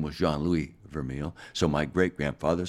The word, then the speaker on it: Vermeil: Well, the catalyst was admiration from my dad, Jean-Louis Vermeil, exactly pronounced Vermeil.